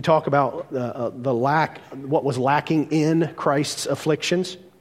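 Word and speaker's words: in (ἐν): Incidentally, do you know how to pronounce the Greek preposition for in? Anybody know that? It's in. Ta-da, Greek. Yeah, it's in. It's pronounced the same way.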